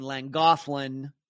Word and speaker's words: Llangollen: Llangollen. Doesn't look like Llangollen, but that's how you pronounce it.